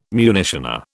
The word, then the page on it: munitioner: British English